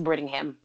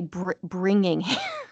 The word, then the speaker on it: Brittingham: Is it Brittingham? – Yes, yeah, Brittingham. – Brittingham. I always want to say Bringingham.